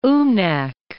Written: Umnak

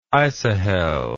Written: isohel

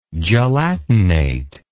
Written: gelatination

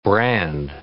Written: brand

